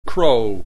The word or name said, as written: Crow